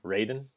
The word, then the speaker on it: Raiden: Raiden, yep. – Raiden. – Fair enough. I mean, that is the white man's way of saying it. It's Raiden, yes.